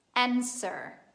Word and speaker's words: answer: Answer. Answer. Vuol dire risposta. Quindi facciamo finta che quella W che si trova nel mezzo non esista. Pronunciamo answer.